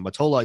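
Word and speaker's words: Mottola: Motola. It's like Motorola without the R's, I think.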